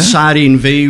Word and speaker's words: Sardine v: And then Sardine V was, it was actually a silent V, but there's a little drop case V at the end.